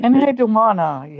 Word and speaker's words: Enheduanna: So I know that you wanted to talk a little bit about Sappho and who, Enheduanna? I don't know how to pronounce her name. – Enheduanna, yes.